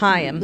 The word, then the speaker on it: Haim: I'm just not like a Haim girly. Haim. I know I always say it wrong. Haim. – They say it's like L'Chaim, but Chaim. Lose the L.